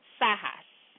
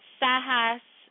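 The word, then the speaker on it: sahas (साहस): A-H-A-S-A. And it's pronounced sahas, okay? So that's courage. So the first word is courage. It's sahas. It is spelled S-A-H-A-S-A, okay?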